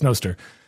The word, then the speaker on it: Noster: so this is like Nost. Like this makes sense to me, so it's Noster.